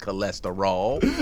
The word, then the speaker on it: cholesterol: You think I left you all, but I stay in your ass like cholesterol. I was like, Cube, you know it's pronounced cholesterol.